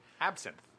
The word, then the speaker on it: Absinthe: I would say absentine or... – Absentine? – How's it pronounced? – Out of the state. Absinthe.